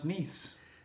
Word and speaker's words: Nice: You say to somebody, hey, I went to Nice, France. They say, oh, I believe it's pronounced Nice. But they still continue to call Paris Paris. Oh, it's not called Paris. It's called Paris.